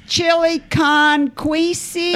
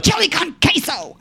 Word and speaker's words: chili con queso: They kept saying, what's this chili con queasy? I had to pronounce everything. I was so mad the whole time. I was like, chili con queso.